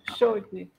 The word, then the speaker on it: Shoji: É Kawika Soji que é o levantador da ah, é seleção. levantador da seleção dos Estados Unidos. O Shoji.